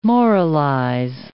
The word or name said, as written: moralize